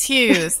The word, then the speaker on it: Hughes: Did I say it right, Sara? – Yes, Hughes.